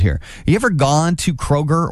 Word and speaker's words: Kroger: People around here cannot pronounce Kroger or Meijer correctly. – No, and it drives me nuts. – They put an S on it and there's no S there.